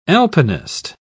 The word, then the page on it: alpinist: British English